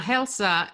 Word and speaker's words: Hälsa: So we are back with Helena and Mika from um, I guess did I pronounce that correct this time? Yeah, Halsa.